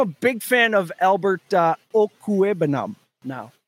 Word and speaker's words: Okwuegbunam: So it's Albert Chumequeca, OKWUEGBUNAM. Okuebunam. Albert Chumequeca Okuebunam.